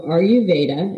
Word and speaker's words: ayurveda: term so i am certified at the counselor level so ayurveda